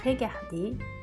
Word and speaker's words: regarder: A pronúncia dos verbos no infinitivo é regarder, travailler e Então podemos dizer que os verbos regulares são esses que, no infinitivo, terminam com o som e.